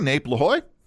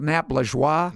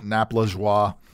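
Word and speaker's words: Nap Lajoie: 1874, Nape La Hoy. – Wait a minute. Isn't it Nap La Joie? – Nap La Joie.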